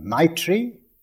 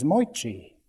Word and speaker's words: Maitri: Because if the teacher, for instance, came from Bengal, he would be pronouncing Maitri as Moitri. It's the way they pronounce it in Bengal.